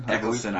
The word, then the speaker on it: Eccleston: Yeah, well, I'm trying to think of also Christopher, say his name? – Eccleston. – I've been saying it wrong. Christopher Eccleston.